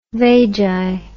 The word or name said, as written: vagi